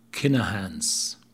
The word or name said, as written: Kinahan's